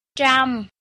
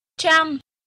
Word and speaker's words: trăm: The word 100 is trăm in Vietnamese. Trăm is spelled as tr-a-m and pronounced as a-m-am, tr-am, trăm, trăm.